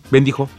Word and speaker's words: bendijo: ¿Se dice bendijo o bendizo? – Bendijo. – Te bendijo. Ah, perrío, lo dije bien.